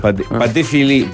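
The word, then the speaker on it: Patek Philippe: Paté Philippe, te lo pronuncio en francés.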